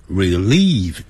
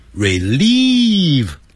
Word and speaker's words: relieve: Help 帮助了 To help somebody to do something 那个 to 啊 T-O 啊可以省掉 So help to relieve my stress To 可以省加上去也对不加也对 So help relieve 可以帮助疏解 Relieve 代表疏解不要念成 Relieve Relieve 长元音 Relieve Eating My stress 就是我的紧张情绪。